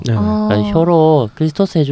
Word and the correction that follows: 클리토리스: 해주는 게 제일 좋았다. 네네. – 클리토리스를. 예, 클리토리스. 아, 예.